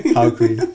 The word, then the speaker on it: Joaquin: Joaquin, again, you can't pronounce it. Joaquin. – Joaquin Phoenix. – Joaquin. Joaquin Phoenix. – It's Joaquin.